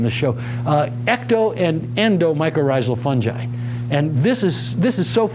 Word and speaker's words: mycorrhizal: This is how you pronounce the words, mycorrhizal.